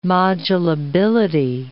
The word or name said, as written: modulability